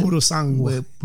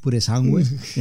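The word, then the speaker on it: Purosangue: Pudosangwe. – Pudosangwe, yeah.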